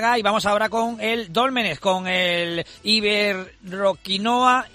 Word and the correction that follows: Iberoquinoa Antequera: y lo digo bien, Iberoquinoa Antequera, el Iberoquinoa Antequera, que consiguió una apabullante victoria en el Argüelles 29-23.